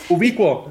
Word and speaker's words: Ubiquo: ¿Se llama Ubiquo o cómo se pronuncia? A ver, tú dinos. – Ubiquo, sí.